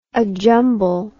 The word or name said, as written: ajumble